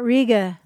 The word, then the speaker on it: Riga: Do you know how you pronounce the town that's spelled R-I-G-A, like the city in Latvia? – It's not Riga? – It's not Riga.